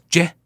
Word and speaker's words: Jeh: Jeh Johnson. Remember that guy? Jeh Johnson. But he pronounced it J, but it was spelled J-E-H.